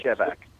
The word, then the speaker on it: Shevack: I pronounce it Shevack.